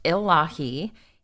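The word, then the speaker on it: Illahe: It is pronounced Elahi, is a local Chinook, however you want to say it, word meaning earth or place or soil.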